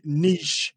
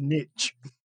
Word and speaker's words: niche: Please just try and say it French, not just niche.